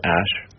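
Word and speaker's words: Æ: The A-E is like pronounced Ash.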